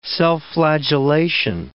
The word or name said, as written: self-flagellation